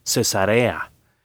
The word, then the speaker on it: Cesarea: Muchas personas cristianas, al leer la Biblia, pronuncian mal el nombre Cesarea, porque existe la palabra cesárea, y una cesárea es una operación que se utiliza a veces en partos difíciles, y esa palabra lleva acento en la antepenúltima sílaba, o sea, debe haber un tilde arriba de la primera A. El nombre de estas ciudades no lleva acento, así que se pronuncia Cesarea.